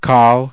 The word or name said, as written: kal